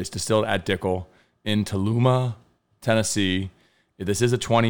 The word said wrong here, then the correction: Tullahoma: Tullahoma. Tullahoma is how you say it? Tullahoma. Uh, yes.